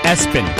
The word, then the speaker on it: ESPN: Then you had Lester, Archibald, Mulva, Todd, Bertha, Navia, which is heaven backwards, and ESPN, which is pronounced Espen.